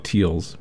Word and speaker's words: Teilz: And we began with something new from LA's Tiles, which is a person's last name, and it's spelled T-E-I-L-Z, so I'm pronouncing it like it's German, where if it's E-I, it's the I sound, and the I-E is the ear sound, so she may actually be called Teals.